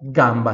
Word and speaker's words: gamba: A única diferença aqui da pronúncia desse GA para a língua portuguesa é que o A no italiano é bem mais aberto, então eu falo gamba, gamba, né?